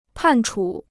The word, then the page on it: 判处: pàn chǔ